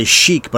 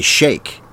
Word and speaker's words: sheikh: Oh, we also say sheik, but it should be sheikh, as an Iranian sheik, but it's a sheikh.